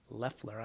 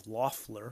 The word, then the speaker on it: Loeffler: I now know how to say her name correctly. Loeffler, I was saying Loeffler for a while.